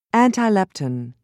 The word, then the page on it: antilepton: American English